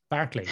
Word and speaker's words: Barclay: Barclay. – Barclay. As in Barclays Bank.